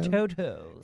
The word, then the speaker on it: Toto: Is it Christian Toto? – Yes. Like the dog. – Is that how you say it? Toto? – Toto. – Yes, Christian Toto.